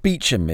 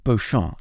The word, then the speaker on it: Beauchamp: Lord Beecham is our first character from LGBTQ plus history who we're going to talk about. It's spelled, for anyone who wants to look it up on the internet, B-E-A-U-C-H-A-M-P. So Beauchamp, if anyone's speaking French out there. So which property is Lord Beecham connected to? Whereabouts is it in England? – So yes, Lord Beecham, or if you're saying it as it's spelt, Beauchamp or whatever, Beauchamp, is connected to Woolmer Castle, which is in the town of Woolmer on the Kent coast, southeast England.